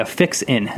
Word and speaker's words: Fixin: Yeah, Fixin.